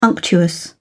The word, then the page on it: unctuous: British English